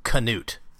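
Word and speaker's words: Knute: His name is actually pronounced Canute, like Rudy pronounces it in the film.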